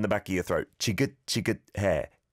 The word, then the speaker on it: 지긋지긋해: If I had to nitpick, I'd say the chia has to be pronounced a bit more like a j, right? So, 장마가 정말 지긋지긋해.